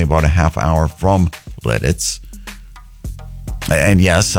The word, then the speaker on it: Lititz: L-I-T-I-T-Z. I've got to be careful how I pronounce that. Lititz, I believe is how you say it.